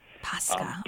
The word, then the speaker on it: Pascha: Pascha.